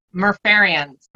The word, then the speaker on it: Murph'Ariens: Murph'Ariens.